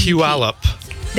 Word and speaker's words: Puyallup: P-U-P-U-Y-L-A-L-L-U-P. – Puyallup.